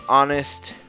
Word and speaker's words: honest: La palabra honesta empieza con una H, pero esa H es muda. Así que la pronunciación de la palabra tiene el sonido de una vocal.